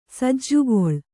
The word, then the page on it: ಸಜ್ಜುಗೊಳ್: sajjugoḷ